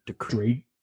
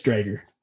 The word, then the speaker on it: Driedger: Because it's spelled D-R-I-E-D-G-E-R. – DeKrager? – So it's not DeKrager at all. – Okay, it's Drager? – I think it's Drager.